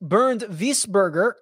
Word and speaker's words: Wiesberger: Wiesberger, or properly pronounced Bernd Wiesberger, yeah, plays well.